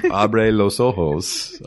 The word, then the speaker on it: Abre los Ojos: Abre los Ojos.